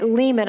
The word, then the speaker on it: limen: And she said the word comes from a Latin a Latin word and it, limen. I guess I'm saying that correctly, but the the word literally means threshold.